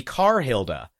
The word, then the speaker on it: Carhilda: But every time you guys say Cahilda, and I'm like, so is that the R? Is that the English R, the non-rhotic R? And yeah, it was. So her name is actually Carhilda. – Yeah. – And I have been saying it wrong this entire time.